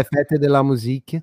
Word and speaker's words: Fête de la Musique: é Fête de la Musique.